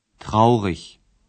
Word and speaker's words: traurig: ولی متوجه باشین که در زبان آلمانی ای گ که در آخر صفت میاد ایش تلفظ میشه، نه ایگ. – Traurig.